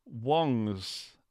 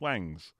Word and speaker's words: Wong: Wongs series of amps, which people refer to as Wangs. And now hopefully with Shang-Chi, people will actually know how to pronounce Shang and Wong rather than Wang.